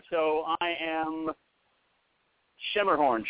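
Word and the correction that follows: Schermerhorn: I think. How do you pronounce it? Because I don't think I've ever pronounced it the way you did. – Skimmerhorn.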